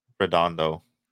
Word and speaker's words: Redondo: All right, so let's get into Redondo. So all signs point to the fact that we talked about this last episode. How do you say it right? – Redondo.